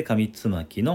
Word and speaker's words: 上巻: 今回は古事記と書いて古事と上巻と書いて上つまきの音読をさせていただきます。